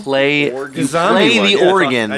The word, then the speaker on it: Oregon: Ooh, watch your pronunciation. – Oregon. – It's Oregon.